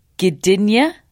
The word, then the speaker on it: Gdynia: and that is moving to Gdynia, Poland.